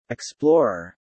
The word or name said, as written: explorer